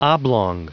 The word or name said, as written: oblong